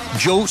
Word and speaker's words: Djo: Joe se escribe D-J-O. Es el actor Joe Carey que conocemos por Stranger Things, el que interpreta a Steve, pero ojo, lo encuentran como D-J-O.